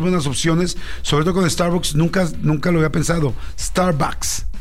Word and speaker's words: Starbucks: Lo decimos Starbucks y se pronuncia Starbucks. – Starbucks con A.